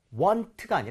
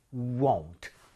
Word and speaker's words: won't: 입모양을 여러분 모으셔야 돼요. 그래서 want가 아니라 won't. u부터 출발하셔도 좋아요.